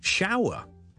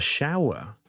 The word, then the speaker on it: shower: 譬如 shower。 S-H-O-W-E-R 一个洒水的冲凉啦 ,ok, I'm gonna have a shower, 这个是一个很完整的发音，但我懒少少可以读成呢 ,I'm gonna have a shower, I'm gonna have a shower, shower, shower, 亦都可以。